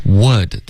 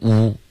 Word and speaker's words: would: Would 的这个的音一定要发出来，千万不要把 would 这个字呢发成 u. OK, 这个 u 的发音是会导致误解的，OK 是错的。